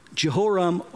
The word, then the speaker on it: Jehoram: So there's now two kings, one in Israel, one in Judah, and both of them are called Jehoram or Joham, whatever way you want to pronounce it.